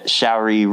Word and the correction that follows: Saoirse: Saoirse Ronan. – How do you say it? Saoirse Ronan. – Saoirse. – Rhymes with inertia.